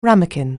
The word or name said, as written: ramekin